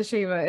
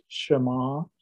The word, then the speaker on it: Shema: Can you explain what the Shema is and why you said that it was critical? – Sure. Well, the Hebrew word at the beginning of Deuteronomy 6, verse 4, is properly pronounced Shema.